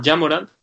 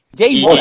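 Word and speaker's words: Ja Morant: Incluso él mismo ha dicho que se llama Jamoran. Es Jamoran. Lo ha llamado Jay. – Jay Moran.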